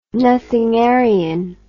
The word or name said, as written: nothingarian